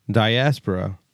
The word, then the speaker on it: Diaspora: Diaspora, am I saying that right? – Diaspora.